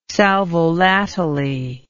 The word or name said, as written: sal volatile